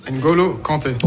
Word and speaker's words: N'Golo Kanté: N'Golo Kanté.